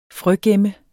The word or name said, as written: frøgemme